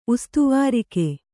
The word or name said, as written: ಉಸ್ತುವಾರಿಕೆ